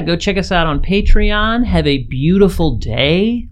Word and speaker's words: Patreon: Just popping in a little early before the episode to tell you guys that we have a Patreon now, and it's very exciting. I just pronounced it Patreon, but it's actually Patreon.